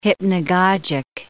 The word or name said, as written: hypnogogic